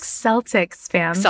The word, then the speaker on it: Celtics: Celtics fans.